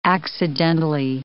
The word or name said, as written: accidentally